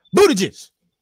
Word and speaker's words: Buttigieg: Buttigieg, or how do you say his name? Is that right? Pete Buttigieg.